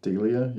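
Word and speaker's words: D'Elia: D-E-L, so D apostrophe E-L-I-A. – Delia? – Delia, yeah.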